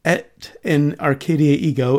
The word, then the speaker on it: Et in Arcadia Ego: And the band was reportedly inspired by the Nicolas Poussin painting Et in Arcadia Ego, and I've checked multiple sources, that actually is the correct pronunciation, also known as the Arcadian Shepherds.